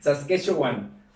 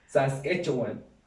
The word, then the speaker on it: Saskatchewan: Saskatchewan. Le puedes decir los Rogue Riders o los de Regina. Saskatchewan.